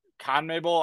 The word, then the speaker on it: CONMEBOL: Uh, being in the com- uh, CONMEBOL. I don't know how to pronounce it. – Conmebol, Basically, yeah.